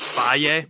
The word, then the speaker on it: Fage: It looks like it says, like, Fage or Fage, but it's pronounced Faye, F-A-G-E.